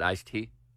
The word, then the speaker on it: iced tea: So you just weren't, you weren't pronouncing the D, you didn't think there was a D there. – No, it's iced tea. – I-C-E space. – It's iced tea. – T-E-A, okay.